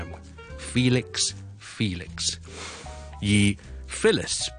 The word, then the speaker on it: Phyllis: Felix F-E-L-I-X 这个英文名同埋 Phyllis P-H-Y-L-L-I-S 这两个发音有什么分别呢？